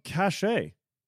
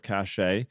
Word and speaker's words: Cachet: Cache. version 3. I believe I'm saying that correctly. When I talked to James Brooks about it at Laracon, that's how I said it, and he didn't correct me. So I'm guessing that's what it is.